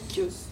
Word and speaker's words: Chios: Donc la scène se passe sur l'île de, je ne sais pas comment on la prononce, Chios ou Chios. – Chios.